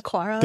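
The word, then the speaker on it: Aqara: Aquara? – Oh, yes. – Aquara.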